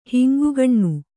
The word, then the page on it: ಹಿಂಗುಗಣ್ಣು: Kannada